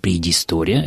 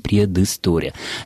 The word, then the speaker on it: предыстория: Вслед за произношением мы произносим здесь «ы». Мы не можем сказать «предыстория», это будет звучать фальшиво, поэтому и пишем «предыстория».